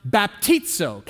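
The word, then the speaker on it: baptizo (βαπτίζω): In fact, the word baptize here comes from a Greek word, and it's pronounced baptizo. Can you say that? Baptizo.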